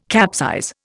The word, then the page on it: capsize: Indian